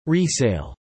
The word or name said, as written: resale